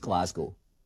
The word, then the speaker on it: Glasgow: Basically, that first noise is not glass. How is it pronounced? Well, that's negotiable. So, the most common way you'll hear it pronounced is Glasgow. So you hear that it's Glas, not glass.